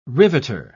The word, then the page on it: riveter: British English